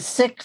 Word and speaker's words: Sikh: And I want to let our listeners know that S-I-K-H is pronounced Sikh and not Sikh. So tonight we're going to say Sikh. You've probably heard Sikh, but it's really Sikh.